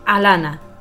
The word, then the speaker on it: Ilana: Cuidado, que se escribe Ilana, pero se dice Alana, no somos nadie.